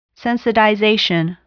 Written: sensitization

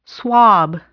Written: swab

